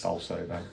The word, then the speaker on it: Bolsover: The 23rd, we'll be doing a 22-mile walk around Bolsover, I think I said that correctly. – Hopefully, yes. – Bolsover, yeah.